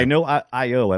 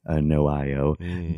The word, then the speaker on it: NOIO: By NOIO, I believe is how they say it. It's pronounced, the developer is N-O-I-O. I'm assuming it's NOIO.